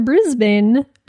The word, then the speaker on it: Brisbane: PPS, it's Brisbane, not Brisbane. Or affectionately, Bris Vegas, like Las Vegas. – Oh, wow. – Brisbane.